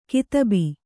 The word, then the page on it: ಕದುಬು: kadubu